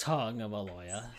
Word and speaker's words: Tagovailoa: Tagovailoa? – Yeah, it's Tagovailoa.